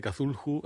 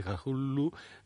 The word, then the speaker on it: Cthulhu: porque se escribe C-T-H-U-L-H-U. Hay quien dice Cthulhu, hay quien dice Cthulhu, me da igual.